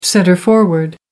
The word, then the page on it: centre forward: American English